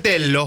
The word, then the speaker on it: Tello: Bueno, es Telo. – No, pero no es Telo. – Sí, es Telo. Se pronuncia Telo.